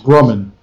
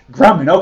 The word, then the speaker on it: Grumman: it's actually pronounced Grumman. – Grumman, okay, all right.